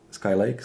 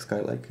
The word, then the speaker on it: Skylake: Skylake, Skylake, já nevím, já to nemůžu vyslovit nikdy. Jestli to je jezero, tak je to like. Lake.